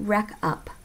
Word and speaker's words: RecUp: I'm assuming you pronounce it RecUp. It's all one word, R-E-C-U-P.